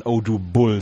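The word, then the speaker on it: Odúbel: Und übrigens wird er, äh, es gibt ja bei jedem MLB-Profil gibt's ja die Pronunciation ähm, da soll man Oh Bull sagen.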